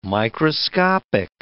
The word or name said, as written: microscopic